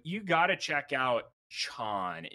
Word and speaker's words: Chon: C H O N.